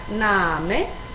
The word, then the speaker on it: Name: olviden de pronunciar la E final. En alemán, recordad esta regla, se pronuncia todo casi como se escribe. Por ejemplo, la palabra Name se pronuncia tal y como se escribe.